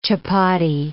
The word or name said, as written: chappati